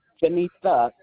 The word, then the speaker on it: Jenetha: Janetha Simmons. – What's your first name, ma'am? – J-E-N-E-T-H-A. – That was J-E-N-E-P-H-A. Is it pronounced Janetha? – Janetha, T as in Thomas, T-H-A. – T-H-A. So that's J-E-N-E-T-H-A.